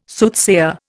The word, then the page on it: Soothsayer: Indian